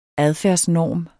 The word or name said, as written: adfærdsnorm